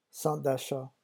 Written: centre d'achat

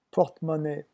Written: porte-monnaie

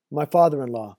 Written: my father in law

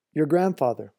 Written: your grandfather

